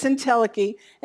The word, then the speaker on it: entelechy: Isn't that a cool, I even looked up how to say it in case that C-H-Y, you know. But it's entelechy,